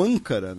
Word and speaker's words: Ankara: Uh, na capital da Turquia, né, Ankara, né, ou, ou, Ankara, né, que é a pronúncia, né, uh, uh, entre muitas aspas, correta, né, nós tivemos dois homens que estavam com explosivos.